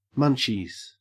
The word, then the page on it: munchies: Australian English